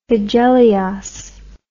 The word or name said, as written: hegeleos